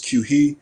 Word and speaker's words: Quchee: But as I got older and I realized how unique it was, and obviously the spelling is different from the pronunciation. It's Quchee mm-hmm.